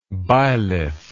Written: bilith

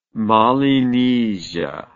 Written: Mollienisia

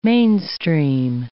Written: mainstream

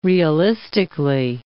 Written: realistically

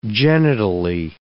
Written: genitally

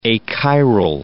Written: achiral

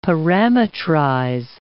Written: parametrize

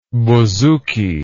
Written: bousouki

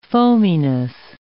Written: foaminess

